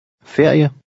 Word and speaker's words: ferie: Here, each letter is pronounced. – Ferie.